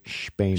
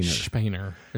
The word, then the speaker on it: Spener: But I have it written down from that resource by Mark Knoll as S-P-E-N-E-R. – Uh-huh. – But I think the sophisticates, they say Spainer. – Spainer.